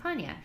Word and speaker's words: Paine: And that's three words, Torres del D-E-L Paña, and that's P-A-I-N-E, spelled like Thomas Paine, but pronounced Paña.